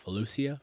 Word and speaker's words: Volusia: So I hope I pronounced that right. Volusia? Yeah, Volusia County.